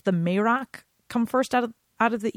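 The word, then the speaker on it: Marach: So we have the kindred of Beor, Marak. I think it's a hard C sound. It's a C-H, M-A-R-A-C-H, Marak.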